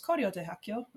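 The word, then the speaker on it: Koryo-daehakyo (고려대학교): also pronounced Koryo-daehakyo, that's the proper pronunciation, and I did that for my sophomore year.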